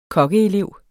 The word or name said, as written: kokkeelev